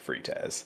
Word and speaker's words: frites: "I'm going to get the steak frites." – I fucking hate you. – And then he goes, "It's pronounced frite," and he goes, "It's French. The S is silent." And I say very confidently, the S wasn't silent when you just said it.